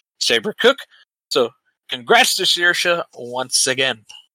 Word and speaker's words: Saoirse: In the end, no one was able to keep up with the pole sitter Saoirse Fitzpatrick from Ireland.